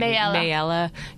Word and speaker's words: Mayella: Mayella Yule, mm-hmm.